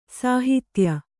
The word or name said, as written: ಸೌಹಿತ್ಯ